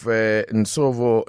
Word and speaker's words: Nsovo: Let's speak to the founder of Nsovo Learning Academy, which specializes in sign language training.